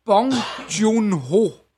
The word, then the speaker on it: Bong Joon-ho: Det, det er ham, instruktøren, der hedder Bong Joon-ho. Og det udtaler jeg sikkert helt Det lyder rigtigt.